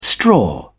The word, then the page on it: straw: British English